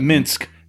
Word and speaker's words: Minsk: Minsk.